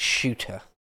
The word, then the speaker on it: Ceuta: I had to look up how you pronounce that. Google said it's Ceuta.